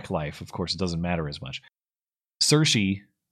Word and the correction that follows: Saoirse: am I saying that right? Or Cersei? I forget. – Saoirse. – Saoirse. I'll never get it.